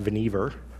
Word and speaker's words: Vannevar: First, his first name is pronounced Vannevar, I found out.